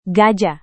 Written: gajah

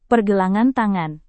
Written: pergelangan tangan

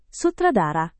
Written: sutradara

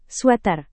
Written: sweter

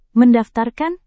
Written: mendaftarkan

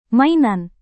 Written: mainan

